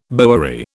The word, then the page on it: bowery: British English